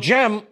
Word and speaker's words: Cem: That's spelled C-E-M, and in modern Turkish, the initial C is a J sound.